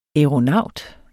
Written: aeronaut

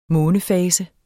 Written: månefase

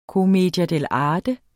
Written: commedia dell'arte

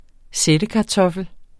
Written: sættekartoffel

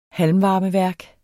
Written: halmvarmeværk

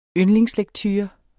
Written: yndlingslekture

